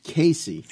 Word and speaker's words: Cayce: Edgar Chase, pronounced Casey.